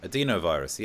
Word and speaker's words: adenovirus: Mark says, I'm not sure about the mRNA, but the AZ vaccine uses a vector, a modified chimpanzee endenovirus. – Adenovirus. Yeah, I think it's pronounced adenovirus.